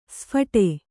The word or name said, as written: ಸ್ಫೋಟ